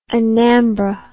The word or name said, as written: anambra